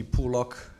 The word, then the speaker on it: Pool Lock: You pronounce that Pollock or Pollock? – Pool lock. – Pool lock. Yeah. I've been saying Pollock all this time. No, like pool lock.